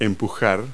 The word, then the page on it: push: verb